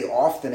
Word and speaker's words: often: The T is silent, but if you say often, ain't nobody going to call you crazy. The T is silent, though. It's really often.